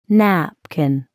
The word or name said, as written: napkin